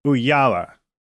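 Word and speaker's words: Ooyala: And before that, I think she was at, is it pronounced Ookla? I never know how to pronounce that. But I think if you're in the industry, you've probably heard of it. Uyala. I'm looking on her LinkedIn page to see how to pronounce it. Uyala. I said Ookla. That was totally off.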